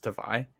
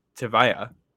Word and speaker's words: Tavai: And going back for a second, I did learn today that it's not pronounced Tavai. It's Tavaya.